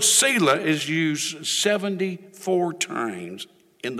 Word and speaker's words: Selah: The word Selah, now it's pronounced different ways. In the UK, it's pronounced Selah. So I'm going to pronounce it Selah. I think the ancestry goes back to the UK, so I'll pronounce it Selah. The other way it's pronounced is Selah. Selah. And then there's a third way, Selah.